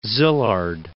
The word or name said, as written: Szilard